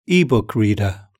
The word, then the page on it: ebook reader: British English